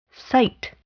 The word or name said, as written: cite